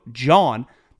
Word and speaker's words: John: So just download the Game Time app, buy a pair of tickets, use the promo code John, that happens to be my name, J-O-H-N, that's how we spell it around here, J-O-H-N, promo code John, $20 off your first pair of tickets.